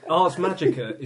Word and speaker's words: Ars Magica: Ars Magica is actually supposed to be pronounced, apparently, according to somebody who actually wrote it, Ars Magica.